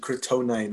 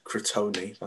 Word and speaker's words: Crotone: And it's Crotone. Another team that have been actually Crotone, not Crotone. That's not good pronunciation.